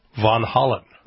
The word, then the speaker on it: Van Halen: So you pronounce it in Dutch. Van Halen.